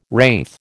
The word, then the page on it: wraith: American English